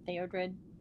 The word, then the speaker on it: Theodred: After a long silence, Theodred inhaled deeply as he attempted to calm his nerves. The silver moonlight draped itself lightly around his form. Am I saying his name right, Theodred? – Yeah.